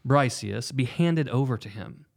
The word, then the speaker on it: Briseis: He then demands that the concubine of Achilles, a girl named Briseis, Briseis.